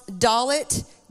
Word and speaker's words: Dalet: It's pronounced Dalet, D-A-L-E-T.